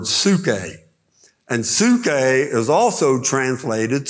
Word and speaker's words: psuche: But soul predominantly, and I challenge you, get a concordance and look the word psuche up. It's spelled P-S-U-C-H-E.